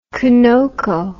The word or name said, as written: conoco